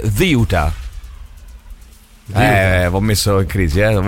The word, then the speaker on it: Ceuta: Come se ci fosse il TH inglese, Ziuta. Okay. Ziuta. Con l'accento rigorosamente sulla prima sillaba, ragazzi.